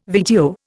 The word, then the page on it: VTO: Indian